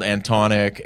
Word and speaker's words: Antonik: I've been called Antonik, Antoniak, Ananook. That's my favorite one.